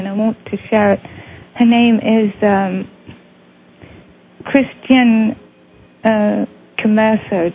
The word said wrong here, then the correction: Camerer: Did I say that correctly? – Actually, he's a Frenchman. It's Camerer.